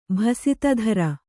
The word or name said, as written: ಭಸಿತಧರ